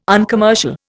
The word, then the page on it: uncommercial: Indian